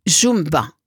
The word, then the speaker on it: Yumba: En fait, qui se prononce Jumba.